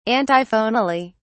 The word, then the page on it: antiphonally: British English